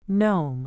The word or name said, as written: gnome